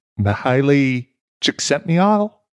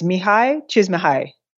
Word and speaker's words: Mihaly Csikszentmihalyi: Is that about right? – Sorry. No, that's not it. Okay. So I had a friend who was Hungarian. – I can't. I shouldn't have even tried. – It's okay. So this is my closest approximation to it. It's Mihaly Csikszentmihalyi. – That's it. Oh, there's far fewer syllables than I thought.